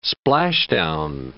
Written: splashdown

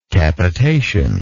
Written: capitative

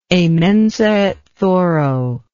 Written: a mensa et thoro

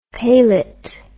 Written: palet

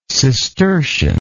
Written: cistercianism

